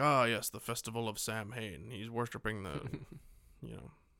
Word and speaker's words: Samhain: Which isn't pronounced Samhain like it's spelled. – Yeah, they get that right in this one. They call it Samhain in this one.